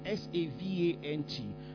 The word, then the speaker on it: savant: It's called SAVANT, but it's pronounced savant. You know, it's from the French.